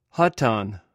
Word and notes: hotan: greek

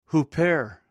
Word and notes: huper: greek

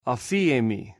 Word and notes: afihmi: Greek

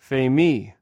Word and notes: fhmi: Greek

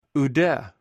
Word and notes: oude: greek

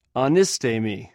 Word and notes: anisth: Greek

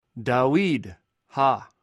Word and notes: dauid: Greek

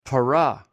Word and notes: para: greek

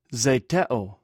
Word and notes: zhtew: greek

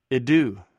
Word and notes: idou: greek

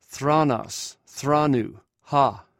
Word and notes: qronos: greek

